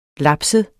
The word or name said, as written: lapset